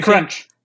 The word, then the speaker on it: TechCrunch: creo que siempre se me olvida cómo se pronuncia. – TechCrunch. – Ajá, es increíble.